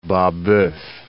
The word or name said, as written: Babeuf